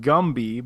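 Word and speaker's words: Gumby (건배): And actually, in Korean, the word is pronounced Gumby, but I thought he meant like the 1960s stop-motion claymation character Gumby.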